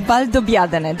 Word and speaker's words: Valdobbiadene: Near Conegliano Valdobbiadene, where the best Prosecco is produced. – And you're the only one who can say that name perfectly. – Conegliano, yeah. – Yeah, Conegliano. Valdobbiadene? – Valdobbiadene, that's the correct pronunciation.